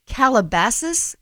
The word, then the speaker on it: Calabasas: And finally, Bret Michaels' daughter, Rain, is on the new Netflix reality show, Calabasas Confidential.